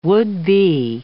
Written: would-be

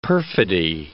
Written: perfidy

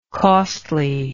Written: costliness

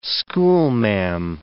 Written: schoolma'am

